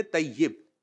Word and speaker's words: طیب: طیب بولا کریں, طیب طیب کر رہے ہوتے ہیں, صحیح تلفظ ہے طیب.